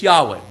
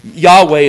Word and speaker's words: Yahweh: Y-H-W-H, the nomen tetragrammaton. Theologians love their terms. But that four-letter name of God. And it's been pronounced many ways, and we don't know for 100% sure how it was pronounced, but in all likelihood, Yahweh is the one that seems to be the best evidence for it.